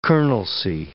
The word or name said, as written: colonelcy